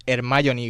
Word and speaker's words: Hermione: Vamos a hablar de Hermione Granger y vamos a hablar con propiedad. Para empezar, llevamos 20 años pronunciando mal su nombre porque este personaje se llama Hermione Granger.